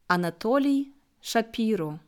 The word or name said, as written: Anatoly Schapiro